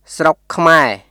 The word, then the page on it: ស្រុកខ្មែរ: スロック・クマエ; srok kʰmae